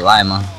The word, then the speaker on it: Lima: Actually, I was in the tank business out there in Lima, Ohio, or Lima. Some people pronounce the Lima. I call it Lima.